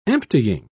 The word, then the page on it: emptying: British English